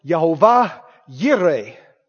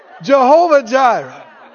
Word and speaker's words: Jehovah Jireh: Now, I guess some folk pronounce it Jehovah Yireh, but that messes up the song. so, We'll just say Jehovah Jireh.